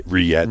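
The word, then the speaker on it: rillette: By the way, are you um just rolling your eyes over there every time we say roulette over here, Simon roulette? no, just, no, our no. mispronunciation. No, no, it's re- riette.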